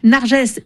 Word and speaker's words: Narges: La semaine dernière, la prix Nobel de la paix Narges Mohammadi a été appelé Narges pendant quelques heures parce qu'il y a eu un doute en conférence de rédaction du matin et que l'équipe a choisi de trancher pour une prononciation. Ce n'était pas la bonne. Narges est redevenue Narges dans l'après-midi et nous sommes nombreux à penser à elle et à sa famille.